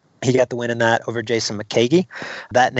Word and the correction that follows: McKeg: It's actually, they corrected me on the pronunciation there, Jed. It's Jason McKeg. – McKeg, okay. – McKeg. Yeah, so now we know.